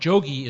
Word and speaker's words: Jogi: The second word, Jogyan, Jogi is the North Indian pronunciation of the term we now commonly use in the Sanskrit form of yogi.